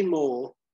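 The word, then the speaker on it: Mohr: Is it pronounced that way? – Oh, yeah, Bob Sugar, yeah. Okay, nice. Yeah. – So it's Jay Moore, which I was never sure if I was going to pronounce that correctly or not, or if it's Mauer or something.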